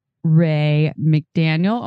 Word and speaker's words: Rae McDaniel: It's interview time, and we are here with repeat guest Ray McDaniel. Oh my God, did I say that right? – Yeah.